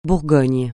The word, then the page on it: Bourgogne: francais